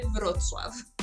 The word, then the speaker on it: Wrocław: É, em português é chamado de Breslávia, é um nome que vem tem origem alemã, mas a, em polonês é Vro, é, se escreve Wrocław com W e se lê Wrocław.